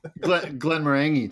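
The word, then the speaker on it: Glenmorangie: Glen Morangi too. It's you got to get the word orange in there. It's Glen Morangi or Morangi.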